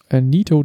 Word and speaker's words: Neato: Neato D85. – Das schreibt man wie Netto? – Nee, wie Neat, also das englische Wort für hübsch, sauber, ordentlich Neato. Und ein O hinten dran.